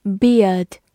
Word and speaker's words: beard: e a r 字母组合有两个发音，英式读作 ear，美式读作 ear，所以 beard 或者 beard 都是对的。